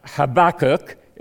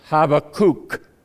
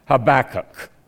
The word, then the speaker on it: Habakkuk: It's not Habakkuk, it's Habakkuk. Think of a happy cook, and that will help you. Habakkuk.